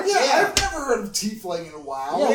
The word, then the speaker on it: tiefling: I've always known it as T. T-E-A. Fling. – Tefling sounds too much like Teflon. So I believe it's T-Fling. – Yeah, I've always pronounced it T-Fling.